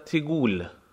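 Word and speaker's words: تگول: اما راجب فعل مضارع متناسب با مخاطب، مخاطب آقا، در افعال در زبان عامیانه عراقی فعل مضارع حرف مضارع یعنی حرف اولش مکسور می شود، برخلاف زبان فصیح که مفتوح می شود. این چنین تلفظ می شود: تو میگی، انت تیگول، انت تیگول، تیگول،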